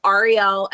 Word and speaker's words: Arielle: Yes, it's Ariel at Ariel Investments.